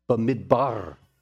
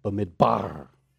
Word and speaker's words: Bamidbar: This is the word, Bamidbar. Bamidbar. Three syllables, B-mid-bar. You don't have to roll the R on the end like I'm doing, but B-mid-bar. Accent on the final syllable, B-mid-bar.